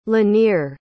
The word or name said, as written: lanier